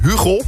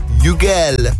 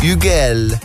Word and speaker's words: Hugel: Veel mensen noemen hem Hugo. Zo schrijf je het, Hugo. Je spreekt het uit op zijn Frans. Hugel. Hugel. Fijn, potato potato.